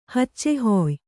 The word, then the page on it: ಹಚ್ಚೆಹೊಯ್: Kannada